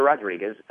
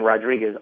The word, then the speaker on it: Rodriguez: But my name is spelled C-E-S-A-R, the letter L. For my middle initial, and then Rodriguez, R-O-D-R-I-G-U-E-Z, .com.